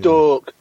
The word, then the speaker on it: Stoke: It's pronounced yeah, Stork.